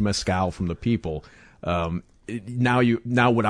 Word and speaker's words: mezcal: And we are talking mezcal. Am I saying it right? – Yes. Mezcal? That's good enough.